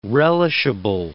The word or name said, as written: relishable